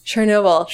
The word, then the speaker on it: Chernobyl: I never know how to pronounce it. Is it Chobani? Chobani. The like nuclear plant. – Oh, Chernobyl? – Chernobyl. Thank you.